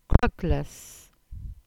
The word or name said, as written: Krugglas